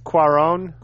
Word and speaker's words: Cuarón: At any rate, man, we're here actually to review Roma by Alfonso, how do you say it, Cuarón? – I think it's Cuarón.